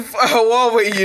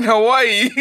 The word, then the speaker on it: Huawei: If you're neither of them and you just got, I don't know, a Huawei phone, it's a Huawei, you dickhead. Huawei. – Huawei. – I'm surprised you didn't pronounce it fucking Hawaii.